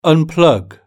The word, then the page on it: unplug: British English